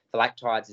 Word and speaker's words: Phylactides: Yeah, I've had some chats, and on the weekend, Nathan Phylactides stood out in a – Phylactides is the way we're going to pronounce it until we're told otherwise. I've heard varying pronunciations but been told to go with that.